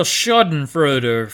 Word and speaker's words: schadenfreude: This game was a real schadenfreude for me.